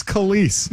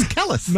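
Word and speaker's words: Kelis: Kellis, famous for milkshake. Yeah. – Oh, God, you do this every year. – I know. – It's Kellis. – No, it's Kellis.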